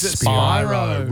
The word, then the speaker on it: Spyro: It's Spyro. Rhymes with Byro.